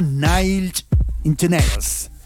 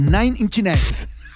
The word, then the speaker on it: Nine Inch Nails: Viernes libre y suena Nine Inch Nails. Lo dije mal, ¿eh? Porque Nine Inch Nails.